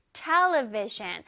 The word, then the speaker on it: television: 자, Television은 앞에 T-E에 강세가 들어가요.